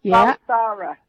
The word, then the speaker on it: Balsara: Balsara.